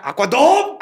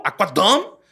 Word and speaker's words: Aquadom: Creo que se pronuncia Aquadom. – ¡Aquadom!